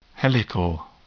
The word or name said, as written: helical